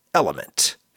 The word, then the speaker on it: LMNT: LMNT is pronounced Element.